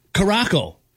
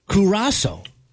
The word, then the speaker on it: Curaçao: Like Caraco, for example. It's pronounced Curaçao. You know, if you were looking at it, you'd be like, that's Caraco.